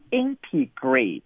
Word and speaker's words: integrate: 我们给大家提示哈，它的发音是 integrate，这个 ate 是不是发成了 eight 啊？